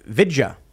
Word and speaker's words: Vijaya: says, on the Joe Rogan podcast, Vijaya pronounced her name Vidya. Vidya. – Hmm. – Good. So I apologize for mispronouncing your name, Vidya.